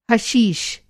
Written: हशीश

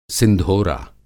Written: सिंधोरा

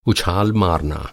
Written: उछाल

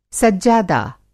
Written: सज्जादा

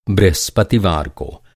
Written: बृहस्पतिवार

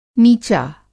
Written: नीचा

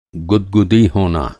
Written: गुदगुदी

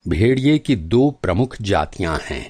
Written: भेड़िया

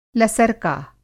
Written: लसरका

